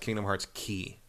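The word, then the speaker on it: Kingdom Hearts Key (Kingdom Hearts χ): I think that's actually supposed to be pronounced Kingdom Hearts Key. – Oh, really? The X is Key? – Because it's C-H-I. It's the letter, the Greek letter is Kai, but they're saying a Key because of Keyblade.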